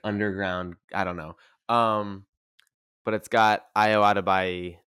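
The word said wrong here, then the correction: Edebiri: from The Bear. – Adebiri. – I'm sorry. – That's the first time I've had to correct them.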